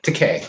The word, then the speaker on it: Takei: It's great pronounced Takei. – Takei. Did I say Takei? – Yes. – Yeah. – Okay. Takei. Thank you. George Takei. – But I've heard him say he doesn't mind when people mispronounce it as Takei, because in Japanese, Takei means expensive.